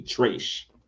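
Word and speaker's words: Trayche: And that is Trash. And just to spell that, it's T-R-A-Y-C-H-E, Trash.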